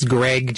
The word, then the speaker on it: Greg: It's Greg, G-R-E-G, then Malvo, M-A-L-V as in Victor, E-A-U-X as in X-ray, .org.